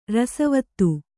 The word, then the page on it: ರಸವಾದ: rasa vāda